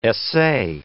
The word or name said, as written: essay